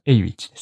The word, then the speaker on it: Awich: エイウィッチです。 – AWICH と書いて、はい、エイウィッチと読むんですけど、